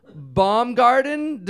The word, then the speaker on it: Baumgarten: Baumgarter. Baumgarter or Baumgarten? – Baumgarten.